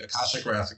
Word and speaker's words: Akashic: I don't know why you think it's crazy to use the word download, because the whole concept of the, I always say this wrong, the Akarthic or the Akarthic record, Akashic record, is that it's basically like a spiritual cloud where you download information.